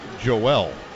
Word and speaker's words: Joel: It's Joel, but it's pronounced Joel. It's like Noel Prefontaine. It's not Noel Prefontaine. It's Noel.